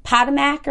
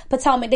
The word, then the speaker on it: Potomac: I pronounced it like Potomac or whatever, but it's The Real Housewives of Potomac.